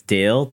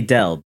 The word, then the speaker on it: Del: I just have to clarify for our audience that his name, you might think that it's Dale, D-A-L-E, because that's how everyone pronounces it. It's actually Del, D-E-L.